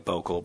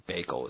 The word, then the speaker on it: Bakel: It's spelled like Bokel, but it's actually Bakel is how you pronounce it.